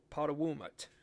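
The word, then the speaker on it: Potowomut: That's the best I can pronounce it, but the pronunciation is Potowumut.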